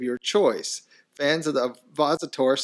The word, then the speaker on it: ovipositor: patented ovipositor. Is that how you say it? – Ovipositor. – Ovipositor.